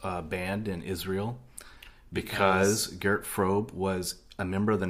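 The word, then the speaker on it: Fröbe: And as we mentioned earlier, it was Gert Frobe. I'm assuming that's how you pronounce it. If it's got an umlaut over the O, it makes it a long O sound. I'm going with Frobe.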